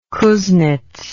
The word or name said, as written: Kuznets